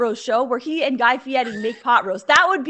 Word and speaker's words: Guy Fieri: I like how you say Guy Fieri. You say it like the problem, like how you're supposed to pronounce it, I assume. – Guy Fieri.